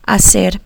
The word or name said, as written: hacer